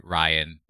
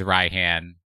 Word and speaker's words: Raihan: My one comment on Pokemon Masters, the announcement, it really aggravated me that they completely validated Steve's pronunciation of Raihan. I'm like, y'all could have said Ryan, but no, you went with Raihan, and now you have...